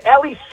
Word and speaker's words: Eli: People ask me, well, is Ellie short for anything, right? It's the Hebrew pronunciation.